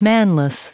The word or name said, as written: manless